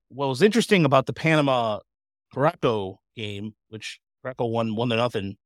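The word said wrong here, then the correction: Curaçao: Am I saying that incorrectly? – Curacao. – Thank you. Curacao game. – Looks like Caraco.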